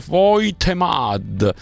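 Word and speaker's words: Woltemade: Il tedescone si scrive Voltemad, si legge Voitemad, e Papasar si legge Papsar.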